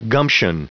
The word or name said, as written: gumption